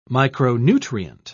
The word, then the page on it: micronutrient: British English